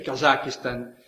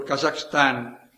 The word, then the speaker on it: Kazakhstan: Qui, buonanotte, io non penso, onestamente, essendoci stato quando ero sottosegretario di sviluppo economico, che sia il Kazakistan o il Kazakhstan, come lo vogliamo pronunciare, l'epicentro.